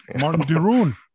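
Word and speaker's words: Marten de Roon: Martin Deroon. – or yeah, so yes, that is the, I guess, the English pronunciation.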